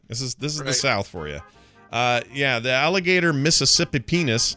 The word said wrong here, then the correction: mississippiensis: How do you say that? – Yeah, Florida's particular alligator. One of them is alligator Mississippi penis. – Piensis. – Oh, piensis. – Mississippiensis.